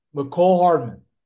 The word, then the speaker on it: Mecole Hardman: Because if it wasn't for that game winning touchdown by Miracle Hardman, I said his name right, didn't I? Mecole Hardman. Mecole Hardman.